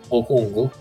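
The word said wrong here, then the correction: Okongwu: Eh, Onyeko Kongwu, sì sì, Atlanta.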